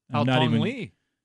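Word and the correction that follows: Haotong Li: Bless you. Say it again one more time. – Haotong Lee. – Haotong Lee. Okay, now at least I know how to pronounce it.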